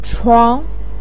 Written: chuang2

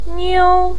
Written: niu1

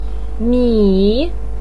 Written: mi3

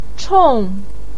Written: chong4

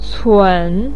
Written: cun3

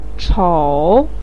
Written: chou3

